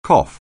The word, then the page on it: cough: anglais